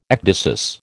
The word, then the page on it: ecdysis: American English